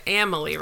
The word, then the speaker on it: Amalie: So, but you went to Amalie Arena today. Again, the only way I know the pronunciation is correct because of the commercials.